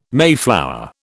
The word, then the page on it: mayflower: British English